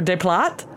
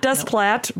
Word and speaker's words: Desplat: And I always am forced to pronounce his name every year and probably butcher Desplat. – It. Yeah, it's not Desplat, no. But maybe it's Desplat, and I shouldn't have pronounced that T.